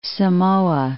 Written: Samoa

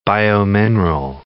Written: biomineral

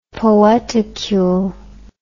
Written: poeticule

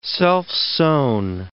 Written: self-sown

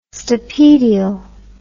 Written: stapedial

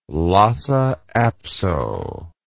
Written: lhasa apso

